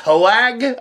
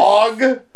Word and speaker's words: Hoag: I guess that's how you pronounce his name? – I guess. – Wog? – I was just saying Hoag. – Hoag? Anyhow, H-O-A-G, folks. H-O-A-G is how you spell his last name.